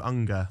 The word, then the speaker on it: Ungar: The final name, Stu Unger.